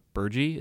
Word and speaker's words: burgee: Ohio, alone in the world, has a flag sometimes called a swallowtail or a bird's mouth, but properly known as a burgee. Is that how you pronounce that word? – Burgee.